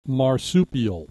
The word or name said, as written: marsupial